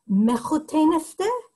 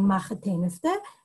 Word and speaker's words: mechuteniste: We get the, and it's technically the proper Yiddish pronunciation is mechuteniste, and some people have it that way, but a lot of Yiddish speakers, and particularly I think a lot of American Yiddish speakers, have the pronunciation machateniste,